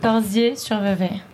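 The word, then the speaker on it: Corsier-sur-Vevey: Le 12 septembre, est-ce que je prononce bien, vous serez à Corsières-sur-Vevey. – Presque. Corsières-sur-Vevey. – C'est juste Corsières-sur-Vevey en Suisse.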